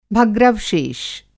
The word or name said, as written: भग्रवशेष